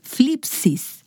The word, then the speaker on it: thlipsis: Yo no sé mucho griego, pero esta me gusta, es flipsis. Y esta es una palabra para aflicción y se escribe THLIPSIS, flipsis.